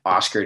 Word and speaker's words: Oscar: Oscar J, spelled J-A-Y, Oscar, just like how you'd spell it the American way, O-S-C-A-R.